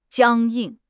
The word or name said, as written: 僵硬